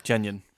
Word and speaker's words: Tynion: Ever heard of him? Or Tynion? – Tynion.